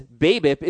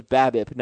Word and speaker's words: BABIP: In the case of the acronym BABIP, batting average on balls in play, pronouncing it as BABIP is wrong. Think of the words you are replacing with an acronym. No one says batting average. It's batting average. The A in BABIP is pronounced like the A in apple. The letters of the acronym should be pronounced and stressed the same as the word they're replacing.